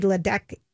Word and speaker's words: Ledecky: And so we posted today on the Lori and Julia show page, Olympic swimmer Katie Ledecky, is that how you say her name? – Mm-hmm.